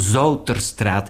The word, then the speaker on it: Zoeterstraat: Goed, de Academiestraat, dat heette vroeger de Zoeterstraat. Letterlijk uitgesproken Zouterstraat.